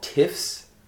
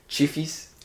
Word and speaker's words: Tiffs: It's called Tiffs, if you pronounce it in English. Portuguese pronunciation is Chifis.